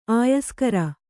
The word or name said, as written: ಆಯಸ್ಕರ